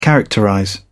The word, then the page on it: characterize: British English